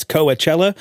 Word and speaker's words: Coachella: So that's Coachella, which takes place in California. Thank you, Fionnuala Jones, for helping me out there. Coachella, of course, as it is correctly pronounced. I was getting it wrong for years, much to Fionnuala's chagrin.